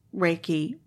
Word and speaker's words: Reiki: And that brings me to another question, which I had, which is, when you're talking about this, as it's different from Reiki, how do you say that? – Reiki.